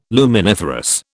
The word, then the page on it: luminiferous: British English